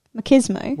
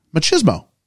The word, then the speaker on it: machismo: The word is spelled M-A-C-H-I-S-M-O. – Machismo. – Wait. Yes. You machismo. People say, yeah, they say machismo. – Yes, we would say machismo. – But that just sounds like cheese.